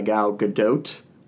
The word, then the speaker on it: Gal Gadot: Basically, the movie is about Diana Prince, played by Gal Gadot, which I have come to the conclusion that that is how you pronounce her name. – Because that's how she pronounces it.